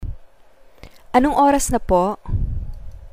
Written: anong oras na po

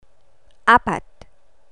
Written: apat

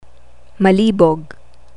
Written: malibog

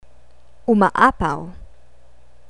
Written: umaapaw

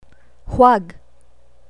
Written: huwag

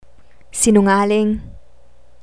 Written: sinungaling

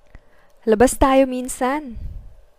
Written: labas tayo minsan

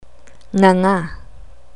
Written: nganga

